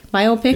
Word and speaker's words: biopic: Biopic. – It's actually biopic, yeah. – He said, might you have the pronunciation of biopic confused with myopic? – 100% yes.